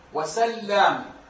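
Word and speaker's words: سلّام: في النطق، سبب الاختلاف في النطق هنا أن اللام عليها فتحة فقط مخففة الأولى، واللام الثانية عليها شدة مشددة، فالنطق هنا اختلف، تقول سلام وسلام، وسبب الاختلاف هو الضبط بالشكل.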